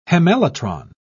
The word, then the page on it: hemelytron: British English